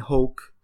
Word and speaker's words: Hoke: We are truly honored that Brian Hoke, his name rhymes with Coke.